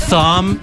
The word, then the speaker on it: Thom: It's pronounced Thom. Thom. I think it's a hard TH, like the you don't say the river Thames, you say Thames.